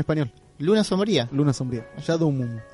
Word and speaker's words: Shadow Moon: Sí, pero ¿cómo se pronuncia bien en inglés? Shadow Moon.